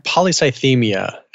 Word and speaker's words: polycythemia: But when you have something like the image that you sent me, which is got relative, am I saying that right? Polycythemia? – Polycythemia.